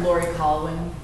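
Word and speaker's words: Laurie Colwin: Lori Colvin. It's spelled L-A-U-R-I-E and C-O-L-W-I-N.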